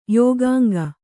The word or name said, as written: ಯೋಗಾಂಗ